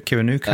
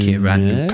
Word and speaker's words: Kiwanuka: It's Michael Kiwanuka. Is that how you pronounce his name? Do you know Michael Kiwanuka? Kiwanaku? Kiwanuka? I think it's Kiwanuka. Kiwanuka. Yeah. I'm going to say Kiwanuka.